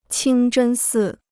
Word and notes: 清真寺: qīng zhēn sì

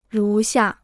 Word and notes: 如下: rú xià

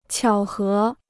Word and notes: 巧合: qiǎo hé

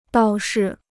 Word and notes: 倒是: dào shi

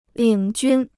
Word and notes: 领军: lǐng jūn

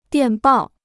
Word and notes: 电报: diàn bào

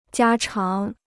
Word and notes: 家常: jiā cháng

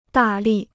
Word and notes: 大力: dà lì

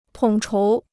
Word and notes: 统筹: tǒng chóu

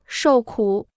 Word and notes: 受苦: shòu kǔ

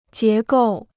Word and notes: 结构: jié gòu

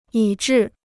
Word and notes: 以至: yǐ zhì

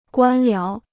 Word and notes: 官僚: guān liáo

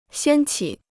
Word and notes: 掀起: xiān qǐ

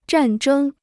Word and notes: 战争: zhàn zhēng

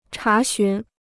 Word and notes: 查询: chá xún

